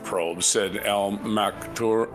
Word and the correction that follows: Maktoum: Al Maktoum, I get his name right, referring to the AE, mission to Mars in February 2021.